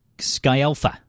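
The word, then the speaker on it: Scialfa: Also 73 is Mrs. Bruce Springsteen, Patti Skafalga. No, how do you say that? Skyalpha, I wasn't even close.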